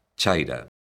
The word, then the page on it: chaira: galega